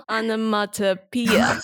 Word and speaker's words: onomatopoeia: 它叫 onomatopoeia